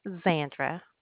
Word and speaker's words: Xandra: Xandra. – Can you spell that? I can't. It's Z as in zebra, A-N-D-R-A.